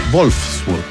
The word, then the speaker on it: Wolfsburg: No, es Wolfsburg, porque la W se pronuncia como V corta, ¿no? O V, ¿no? Se dice V. Y la V, que es la V, la V se pronuncia F, por eso se dice von der, von en el alemán.